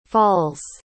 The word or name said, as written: falls